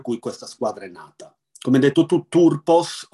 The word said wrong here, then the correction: Thurpos: o sarebbe meglio dire il TH in sardo si legge con una sorta di Z strisciata, Surpos,